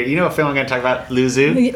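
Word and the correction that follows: Luzzu: Lutsu. – Lutsu. Okay, yeah. I mispronounced it.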